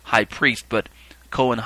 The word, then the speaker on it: kohen: The Hebrew term kohen, spelled with a K-O-H-E-N, which is also spelled sometimes with a C, C-O-H-E-N, kohen, or kohen, as it's pronounced in different ways, means priest.